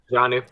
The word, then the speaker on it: Jonnu: Jonnu Smith is – am I saying his name right? – Jonnu.